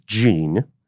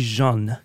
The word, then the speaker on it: Jeanne: It would actually be Jeanne, because she's got two N's and an E.